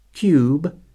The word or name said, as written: cube